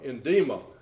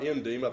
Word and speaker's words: edema: Edema, not endema. I think it's edema is how it's pronounced.